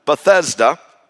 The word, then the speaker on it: Bethesda: And while he was in Jerusalem, he went over to an area called the Pool of Bethesda.